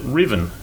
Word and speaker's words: Ruthven: And I have gone identically to Carl, 1, 8, 3, 2, merely noting that the only, that's a surname, that Ruthven, and the only bloke I know who had that surname pronounced it Riven.